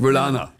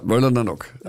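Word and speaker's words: Wöllaner Nock: Nur ich habe gesagt Wöllana. – Ja, hätte Wöllana ich auch gesagt. – Ja, Wöllana. Ja, Wöllanernock. Stress is on the first syllable.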